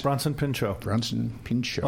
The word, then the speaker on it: Bronson Pinchot: Bronson Pinchot.